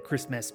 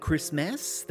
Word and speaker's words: Christmess: And if you're wondering why I'm pronouncing it Christmess, that's because that is the title of the film, and uh, that is also how it's spelt Christmess. So when you're looking for it, it's M E S S on the end instead of M A S.